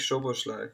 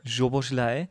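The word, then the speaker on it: Szoboszlai: Hat, hat nicht, äh, Schoboloscha, so, wie spricht man tatsächlich aus? – Dominik Schoboschlei. Schoboschlei.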